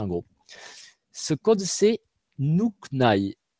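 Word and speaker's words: Nhûkny: Alors, ça s'écrit, je n'ai pas de tableau, mais ça s'écrit N-H-U, accent circonflexe, K-N-Y. Et ça, le, comme le U avait un accent circonflexe, se prononce OU, et le Y dans ce mot-là se prononce I, ça fait Nuknai.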